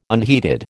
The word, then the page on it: unheeded: American English